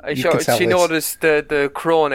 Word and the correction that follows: Gorn: egg. Is it how you pronounce it? – The Gorn. – The Gorn egg, that's correct.